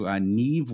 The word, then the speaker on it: Niamh: Because I've been pronouncing her name wrong on the podcast all season. Yeah. So we're going to go down to Niamh Wallace in seventh. N-I-A-M-H. It's just not a name that we have a lot of in the UK.